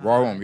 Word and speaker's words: Warholm: Warholm, yes.